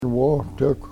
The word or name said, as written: wohtekw